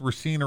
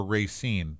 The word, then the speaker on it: Racine: Along with Racine or Racine. Either is acceptable. Either is acceptable in the city of Racine.